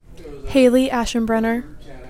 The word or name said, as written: Haley Aschenbrenner